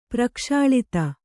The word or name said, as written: ಪ್ರಕ್ಷಾಳಿತ